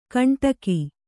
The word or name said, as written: ಕಂಟಕಿ